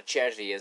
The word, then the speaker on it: Cervi: Okay, a couple of Argentinian announcers, and were they happy to see Cervi on the field, or Cervi, as he, you know, it's pronounced in Spanish or in Italian, as the name is actually an Italian name.